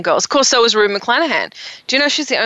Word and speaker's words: Rue McClanahan: Rue McClanahan. – Rue McClanahan.